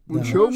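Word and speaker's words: Monchaux: Monjo? zeg ik dat goed? Monjo, ja. Monjo.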